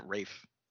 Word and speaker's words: Ralph: And then, I'm pretty sure he pronounces it Rafe. I think so. There's always been weird stuff about his name, like Rafe Fiennes.